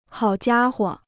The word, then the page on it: 好家伙: hǎo jiā huo